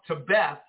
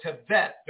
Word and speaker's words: Tevet: And sure enough, it was so strange that we were in the month Tebeth or Tevet, depending on how you say it in the Hebrew.